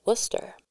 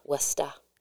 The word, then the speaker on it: Worcester: and that is located in Worcester, Massachusetts. – Worcester. – I dare anyone who doesn't live in Massachusetts to look up that town and try to say it right. It took me years. Worcester.